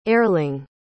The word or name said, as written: Erling